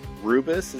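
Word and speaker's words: rooibos: All right, it's always enjoyable to talk to you, and I'm excited to try the Rubis. Is that how you pronounce it? Rubis tea? – R-O-O-B-I-S, yes.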